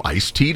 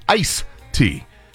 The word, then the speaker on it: iced tea: Do you call it iced tea with a D on the end of the ice, or just ice tea?